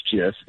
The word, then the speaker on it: GIF: Didn't he come out years ago and say it is GIF with the soft J?